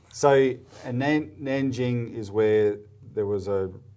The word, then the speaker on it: Nanjing: Is that just a Nanking was under the older sort of pronunciation system. It was Nanking. – Oh, there's a new system. Okay, yeah. Nanjing.